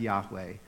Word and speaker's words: Yahweh: That's not just saying generically God or generically Master or generically Lord she is saying, and, and typically we don't pronounce it, Jewish people don't pronounce it, but it's probably pronounced something like Yahweh.